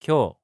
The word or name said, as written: kyou